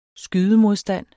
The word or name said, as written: skydemodstand